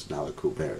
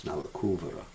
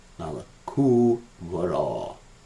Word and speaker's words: Nalakuvara: Many devotees pronounce it as Nalakuvara. It's not Nalakuvara, it's Nalakuvara. Nalakuvara.